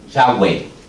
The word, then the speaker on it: Yahweh: entonces ellos lo pronunciaron Yahweh, que es el nombre que nosotros pronunciamos en el día de hoy en español como Jehová.